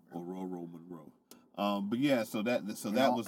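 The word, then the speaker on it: Ororo Munroe: Aurora Monroe.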